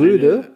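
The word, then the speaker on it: RØDE: Ähm, Ö. Ö? – Also Röde? Eine, eine, eine Ödyssee.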